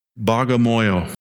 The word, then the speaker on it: Bagamoyo: Bagamoyo.